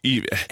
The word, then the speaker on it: Evian: It's pronounced Evian. – Evian. – It's a French town.